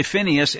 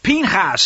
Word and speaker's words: Pinhas: And ultimately, especially in such a time as this, if we don't understand the lessons of this Parsha, and of course it's called Pinhas, some say Phinehas in the English, but I like the Hebrew pronunciation, Pinhas, son of Eleazar, son of Aaron the priest.